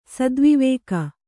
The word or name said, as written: ಸದ್ವಿವೇಕ